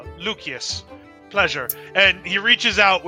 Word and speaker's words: Lucius: So, you know, I thought I'd return the favor, and so I push another beer towards Lucius. – Lucius. There's no soft C in Latin.